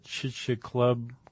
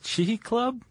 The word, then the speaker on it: Chicxulub: Chicxulub, the Chicxulub. – It's a there's an X in there, but it's in Mesoamerica, so the X is like a hua.